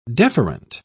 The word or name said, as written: deferent